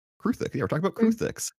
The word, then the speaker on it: Kruthik: Anyways, today we're talking about Kruthiks.